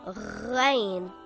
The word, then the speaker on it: Ghain: Ghain is a heavy letter and should be always pronounced heavy. It is as if you are gargling some water from the throat. Ghain.